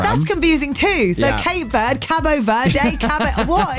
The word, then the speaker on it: Cape Verde: So they have told me it is pronounced Cape Verde. – Oh, okay. – Cape Verde.